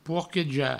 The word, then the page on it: porquejar: pourqueja; provençal